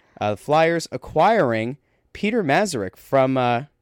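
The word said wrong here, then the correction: Mrázek: is it, did I say that right? Peter Mazurek from. – Uh, no, it's Mrazek.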